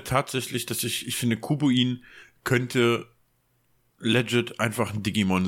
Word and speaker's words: Kubuin: Hm, was meiner Meinung nach eigentlich auch eher in Richtung Digimon geht, ist Kubuin. – oh mein Gott, wie das schreibt man das? Ich, ja, K-U-B wie wie Cube halt, also ein Kubus, Würfel und dann U-I-N wie Pinguin.